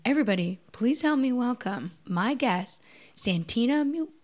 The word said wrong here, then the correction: Santina Mua: Oh, shit, I just said it wrong. Santina Mua, right after this break.